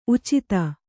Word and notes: ಉಚಿತ: Kannada

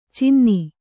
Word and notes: ಚಿನ್ನಿ: cinni

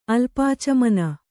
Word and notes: ಅಲ್ಪಾಚಮನ: alpācamana

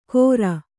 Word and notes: ಘೊರ: kannada

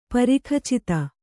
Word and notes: ಪರಿಖಚಿತ: Kannada

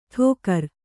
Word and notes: ಠೋಕರ: ṭhōkara

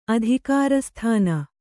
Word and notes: ಅಧಿಕಾರಸ್ಥಾನ: adhikārasthāna

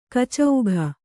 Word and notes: ಕಚೌಘ: kacauga

